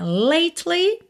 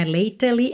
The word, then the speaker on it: lately: Mas olha, lately, você não pronuncia o E que vem depois da letra T, ok? Não é lately, é lately. Lately.